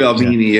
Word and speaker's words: Albini: Also, you work with Steve Albini. Is that how you pronounce his name? Albini? Or is that Steve Albini, yeah.